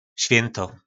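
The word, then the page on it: Svjynto: nářečí Po našimu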